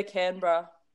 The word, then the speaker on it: Canberra: Yeah, Canberra.